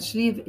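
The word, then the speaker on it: Sliabh: In Irish, there's no V in Irish language, so it's S-L-I-A-B-H. And B-H in Irish gives a V sound at the end of a word, so Slieve na Cailí.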